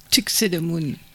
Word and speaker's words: Tuxedomoon: Et on vient d'entendre, alors j'ai toujours du mal à prononcer le nom de ce groupe, Tuxedomoon.